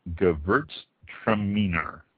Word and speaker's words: Gewürztraminer: Gewürztraminer. This is probably the toughest wine to pronounce and long spelling on it. Spelled G-E-W-U with the little two dots above it, the German two dots. R-Z-T-R-A-M-I-N-E-R. Gewürztraminer.